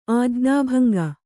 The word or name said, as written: ಆಜ್ಞಾಭಂಗ